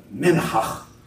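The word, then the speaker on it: minchah: It's minhach. Isn't that fun? Minhach sounds like you're clearing your throat.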